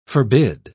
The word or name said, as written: forbid